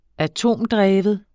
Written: atomdreven

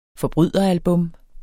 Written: forbryderalbums

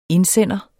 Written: indsender

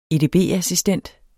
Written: edb-assistent

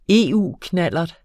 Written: EU-knallert

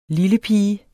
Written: lillepige

